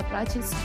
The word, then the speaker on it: Prates: You can find me on Twitter at Nanda, that's NANDAPRATES, Nanda Prates _.